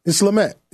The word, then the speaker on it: Smollett: How do you actually say his name? – Smollett. It's Jussie Smollett. – Smollett. – Smollett. – Smollett. – Smollett. Okay. – Smollett. – So it's yeah. – not a French.